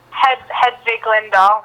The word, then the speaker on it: Hedvig Lindahl: How do you pronounce her name? Helvig Linnehal, isn't it her name? – Hedvig Lindahl.